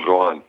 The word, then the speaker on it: Rouen: Und dann ging es noch ein Stück weiter weg von den USA nach Europa und zwar in die zweite französische Liga nach Rouen, wenn ich das richtig ausspreche. – Genau, Rouen, ja, so ist es.